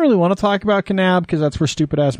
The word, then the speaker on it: Kanab: I wonder if anybody ever looks at the name Kanab and thinks that the N is, or the K is silent like in Knife. – A knob.